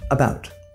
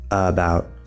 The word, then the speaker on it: about: It is stressed on the second syllable, and remember that in English, syllables that are not stressed will be quieter and shorter, where syllables that are stressed will be louder and longer. So we don't want to say about, that's very even, that's very flat, right?